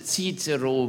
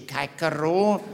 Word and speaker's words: Cicero: We correctly pronounce Cicero. We do not say Caicero.